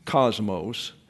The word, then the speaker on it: cosmos: There used to be a television program back decades ago called the Cosmos, but these are short O's here. It's cosmos in the Greek.